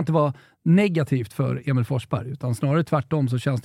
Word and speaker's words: Forsberg: Eller ska vi säga Emil Forsberg? Jag vet inte om du såg det? – Forsberg. – Eh, svårt med uttalet där. Ja, men de hade ju bakat in ett U då efter O som i engelskans four eftersom man nu har gjort mål i fyra raka ligamatcher.